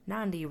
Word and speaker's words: Nandi: Nandi rhymes with Gandhi.